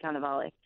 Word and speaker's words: Cannavale: And Bobby, is it Cannavale? Is that how you pronounce it? – Cannavale, yeah.